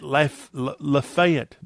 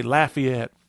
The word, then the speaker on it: Lafayette: at Lafayette? No, did I say that wrong? I'm putting on my Mississippi and Tennessee bro gear, and I should say Lafayette.